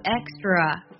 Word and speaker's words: Extra: 益达的名字也比较有趣，它读作 extra。